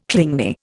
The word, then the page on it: clingy: Indian